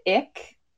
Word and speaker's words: Icke: And it's a conspiracy theory popularized by David Icke. Yeah, his last name is spelled I-C-K-E.